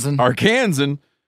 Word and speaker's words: Arkansan: Arkansan.